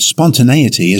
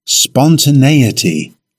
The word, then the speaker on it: spontaneity: Now, the word spontaneity is a noun. It has five syllables. Stress the third syllable. In British English, it's pronounced spontaneity.